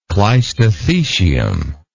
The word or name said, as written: clistothecium